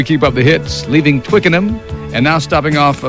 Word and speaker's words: Twickenham: It's Twickenham. – Twickenham? – Yeah.